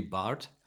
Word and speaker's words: Barth: Yeah, we don't have a TH in German. – You see? – So it's actually Barth.